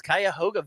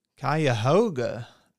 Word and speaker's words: Cuyahoga: Hey, we just got a super chat for $5 from Aaron Jones, Cuyahoga Valley National Park. Now, is that in Ohio? – Wow. – That sounds like an Ohio park. – Cuyahoga. Nice job nailing the pronunciation.